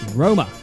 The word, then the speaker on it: Roma: Rome, pronounced Roma, is the capital of Italy.